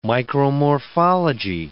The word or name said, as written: micromorphology